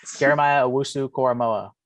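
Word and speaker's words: Owusu-Koramoah: Jeremiah Owusu-Koramoa. – It was close. – No, that's not close. That's dead on.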